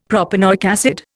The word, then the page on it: propanoic acid: Indian